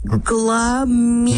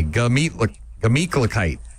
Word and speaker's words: Gemütlichkeit: I'm going to get you to pronounce it properly. Here we go. Gah. – Gah. – Meat. – Meat. – Kla. – Kla. – Kite. – Kite. Gla-meat. – Not a gla. Gah. Just G-A. Gemütlichkeit.